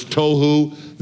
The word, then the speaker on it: tohu: Two words in Hebrew that are easy to remember by pronunciation. One is tohu, the other is bohu. Tohu and bohu.